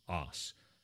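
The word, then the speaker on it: 'Oss: or in the local pronunciation, os.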